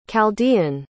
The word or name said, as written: chaldean